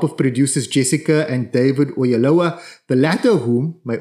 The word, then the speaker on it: Oyelowo: It's just Oyelowo.